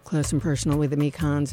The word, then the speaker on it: Mekons: And then, this is probably like the 12th time I've mentioned it this morning, but hey, Mekons, pronounced Me Cons, will be at Bell's Eccentric Cafe in Kalamazoo tonight.